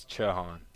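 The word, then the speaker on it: Chau: So for everybody out there, though, what's the correct way to say it? – It's Chahan. – Chahan. – Yeah. – Yeah, come on. But it's C-H-A-U. – I feel like that's normal to see, though, like C-H-Ch, and then the H-A-N is Han, so I feel like.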